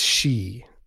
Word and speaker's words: Sidhe: By the way, I looked up the Sidhe, it's she. It's pronounced Slashe, like it's Sidhe. Like it looks like it says Sidhe, but it's she, which is weird.